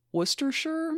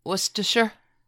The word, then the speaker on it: Worcestershire: And so she, Tenney, and her daughter, Zula, would go on to establish a school in Worcestershire, maybe? Did I say that wrong? Probably. – Worcestershire.